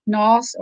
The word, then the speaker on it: Nauss: You know, there's the Noss family. I believe they live in Lunenburg now. Their name is spelled N-A-U-S-S, Noss or Naus.